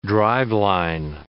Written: driveline

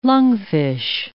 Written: lungfish

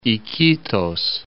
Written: Iquitos

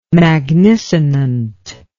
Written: magnisonant

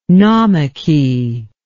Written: naumachy

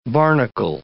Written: barnacle

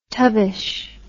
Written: tubbish

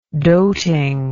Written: dotingness

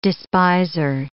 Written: despiser

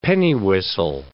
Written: pennywhistle

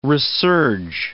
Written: resurge

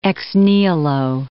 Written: ex nihilo

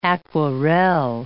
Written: aquarelle

